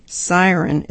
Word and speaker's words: SYRN: Sydney Sweeney, Surin Lingerie, a lingerie line from Sydney Sweeney, not exactly groundbreaking, but the brand, S-Y-R-N, pronounced Siren, is out now.